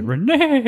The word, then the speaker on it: Reneigh: You have to say Renee. – It is spelled like that. R-E-N-E-I-G-H. And then I have a bird named Sparrow, S-P-A-R-R-O, and he's a workout bird.